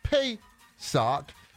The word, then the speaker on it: Pesach: The Hebrew word for Passover means Pesach, which pronounced as Pesach.